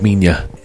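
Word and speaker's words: Minilla: Now, it's spelled like Manila. Manila. But in the American dubs, it was always pronounced Minya.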